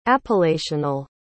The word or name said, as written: appellational